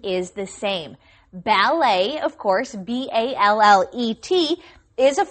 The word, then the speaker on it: ballet: But obviously, the French pronunciation, it sounds a little bit nicer, too. They would say ballet. – Okay, the T is silent. You don't pronounce the T because it's a French word, ballet.